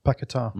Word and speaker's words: Paquetá: Paquetá, mm-hmm.